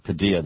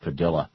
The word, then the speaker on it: Padilla: I know some people pronounce it Padilla. That's how you would think it should be pronounced, but he has asked that it be pronounced Padilla.